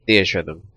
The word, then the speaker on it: Theatrhythm: How do you pronounce it? – I pronounce it Theatrhythm, but Theatrhythm. – No one knows what the hell I'm talking about when I say that.